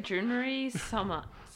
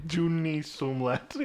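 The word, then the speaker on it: Jurnee Smollett: It's like it looks like Junery Smollett. – Yeah. Junie Smollett.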